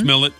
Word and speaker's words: Smollett: Smollett? Jussie Smollett? Smollett? – Yeah.